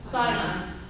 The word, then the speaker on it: Sinai: What's the English pronunciation? Sinai. Thank you.